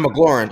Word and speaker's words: McLaurin: No, McLaurin. McLaurin. M-C-L-A-U-R-I-N. – No, it didn't sound right. Okay. Whatever. I knew it didn't sound right saying McLaughlin.